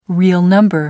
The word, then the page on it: real number: British English